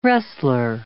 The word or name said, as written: wrestler